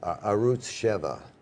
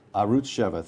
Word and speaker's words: Arutz Sheva: Norman Podhoretz, in an interview in, I will now mispronounce this, Arutz, how is it pronounced? – Arutz Sheva. – Arutz Sheva, thank you.